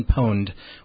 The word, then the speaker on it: Pwned: And how it works, the site is at Have I Been Pwned, which is P-W-N-E-D. It's the word owned but spelled with a P instead of an O.